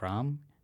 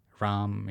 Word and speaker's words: Ram: My name is Dr. Kaushik Ram. Kaushik is spelled K-A-U-S-H-I-K. Ram is spelled R-A-M.